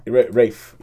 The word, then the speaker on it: Ralph: And it stars Ralph Fiennes, which I believe is how you pronounce his name nowadays. – Rafe.